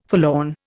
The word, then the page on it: forlorn: Indian